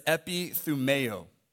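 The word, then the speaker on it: epithumeo (ἐπιθυμέω): The Greek word is epithumeo.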